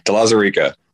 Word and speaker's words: De Lauzirika: And I totally mispronounce that. – No. De Lauzirika.